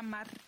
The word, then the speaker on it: Marte: On Facebook, it's Melania Luisa Marte, you Ooh. Know, like Tuesday, you know, but roll your R.